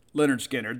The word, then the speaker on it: Lynyrd Skynyrd: All right, so number six on the list from pronounced Leonard Skinner, the debut album.